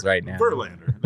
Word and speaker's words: Verlander: Verlander. I can't even say it right now. – No, you're just like, overemphasizing all of the wrong syllables Verlander. That's it. It's easy.